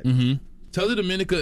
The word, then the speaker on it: Dominica: Tales of Dominica. – Dominico. Yeah. What's that about? – And he's from there and didn't even pronounce it right.